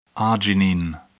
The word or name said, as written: arginine